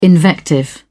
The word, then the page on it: invective: British English